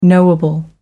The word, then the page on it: knowable: American English